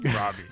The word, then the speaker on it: Robbie: How do you say her name? – Robbie. – Robbie, there we go.